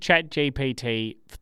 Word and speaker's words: ChatGPT: I've used ChatGPT 3 times.